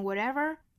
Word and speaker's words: whatever: Whatever happens, 这个 whatever, 美音读成 whatever, 变成了半浊音 flap t 闪音一闪而过 whatever happens to the least of us 注意连读 has an effect on all of us。